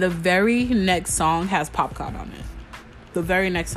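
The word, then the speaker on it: Popcaan: Did I say his name right? Yeah, Popcorn.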